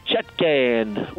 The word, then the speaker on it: Chutkan: Her name is Judge Tanya Chutkan, which rhymes with shut can.